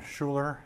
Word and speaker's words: Schuler: Ron Schuler, S-C-H-U-L-E-R.